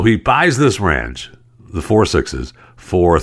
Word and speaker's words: 6666: So it's a 6666 ranch, and they pronounce it 4-6s.